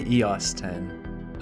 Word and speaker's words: EOS 10: Yeah, we say EOS 10.